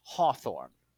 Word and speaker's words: Hawthorne: Now, I'd like to clarify that the name has always been pronounced Hawthorne.